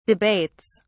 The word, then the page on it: debates: British English